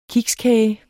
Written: kikskage

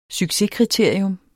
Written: succeskriterium

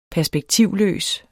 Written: perspektivløs

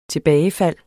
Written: tilbagefald

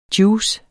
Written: JUICERNES

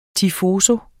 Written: TIFOSI

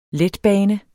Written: letbane

